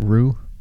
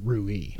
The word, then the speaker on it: Rui: Friggin' Rui Hachimura, 32, whatever. – Isn't it Rue? – He pronounces it Rui. – Oh, he does? – Yeah. – I just thought it was a Rue.